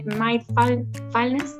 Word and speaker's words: mindfulness: Es psicoterapeuta eh, por racionalista ha hecho atención clínica también con especialidad en adultos, es máster también en mindfulness, lo pronuncié bien, Mindfulness. – Mindfulness.